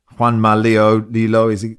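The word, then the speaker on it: Juanma Lillo: Pep wasn't at the game, and it was nice to see Juanma Lilo, is how you pronounce it, taking the ear pods out.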